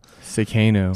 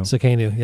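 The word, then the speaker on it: Tsidkenu: Sikenu. – Sikenu, yes. – You spell it with a T. It's crazy. – Yeah, it's a T, kind of a silent T there.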